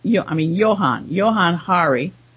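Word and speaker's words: Johann Hari: So now I'm going to go on to, Jonathan Hari, Johan Hari. I guess that's how his name is pronounced.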